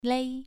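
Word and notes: lei: tone 2

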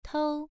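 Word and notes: tou: tone 1

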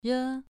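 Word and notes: re: tone 2

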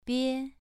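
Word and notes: bie: tone 2

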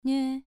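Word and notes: nue: tone 2

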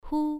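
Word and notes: hu: tone 1

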